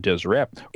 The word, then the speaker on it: Deseret: It's D-S-R-T, but it would have been pronounced something along the lines of Deseret.